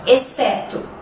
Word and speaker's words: exceto: Sim, às vezes ela é silenciosa. Quer ver? As palavras exceto, exceção uhum, e excelente. Vamos repetir? Exceto, exceção, excelente. Sim, se escrevem com X, mas nós não pronunciamos ele, ok?